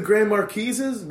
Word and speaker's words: Grand Marquis: So you guys, the Grand Marquises, the Grand Marquis, just just pronounce the S.